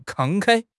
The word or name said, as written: 慷慨